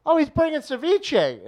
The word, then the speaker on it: ceviche: I go, it's called ceviche. You guys haven't been able to say the name right.